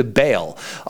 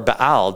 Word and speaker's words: Baal: And then there was human sacrifices to Baal, or Baal, depending on how you want to pronounce that.